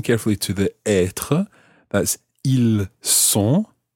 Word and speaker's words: ils sont: But compare it to être. Ils sont. – Ils sont. – So that time it's an S sound, a S. Ils sont. – Ils sont. – So in that situation, you're not pronouncing the S of the il, but you're pronouncing the S of the word sont, S-O-N-T.